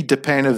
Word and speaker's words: DePena: DePena, V as in Valdez, which is part of my last name. And DePena, I'll spell that one out because it's a bit unusual.